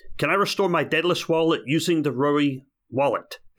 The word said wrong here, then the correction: Yoroi: Of course, I pronounced Yoroi wrong. It's Yoroi. Yes, you can.